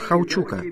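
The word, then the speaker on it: Huachuca: Потом был в том Форт Хаучука. Так вы это произносите теперь.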